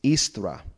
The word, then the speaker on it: Eostre: From the goddess Istra, E-O-S-T-R-E, and there are varying spellings of that, but it's supposedly pronounced Istra.